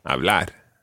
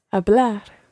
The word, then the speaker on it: hablar: Okay, when you're saying an infinitive, always trying to put the pronunciation, or the stress rather, on the last syllable. Comer. – Comer. – Hablar. – Hablar.